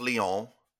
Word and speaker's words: Lyon: Lions. – It's pronounced Lyon.